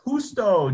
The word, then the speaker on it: Justo: Yes, Justo, J-U-S-T-O, Gonzalez.